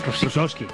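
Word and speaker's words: Rosický: Que fíjate que Karol G ficha a Rosicky. Se dice así, ¿no? – Rosicky.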